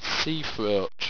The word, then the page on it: seafroach: owstwecian